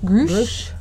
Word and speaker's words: Grusch: His name is David Charles and his last name, because I'm horrible with pronunciation, it's G R U S C H. Grusch. Grusch.